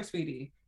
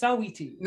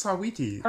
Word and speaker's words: Saweetie: Yeah, congratulations to her in general, because, like, you know, she's Saweetie. Saweetie. I don't call her Sweetie. It's Saweetie. – No, it's Sawiti.